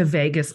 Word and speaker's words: vagus: So pressing into this a little bit, you gave a talk where you mentioned the vagus nerve.